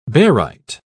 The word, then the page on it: barite: British English